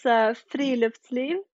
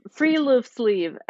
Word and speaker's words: friluftsliv: Yeah, it's friluftsliv. – Okay, I definitely can't say it like that. – Okay, so like free loof sleeve. That's the Americanized pronunciation.